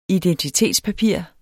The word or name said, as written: IDENTITETSPAPIRER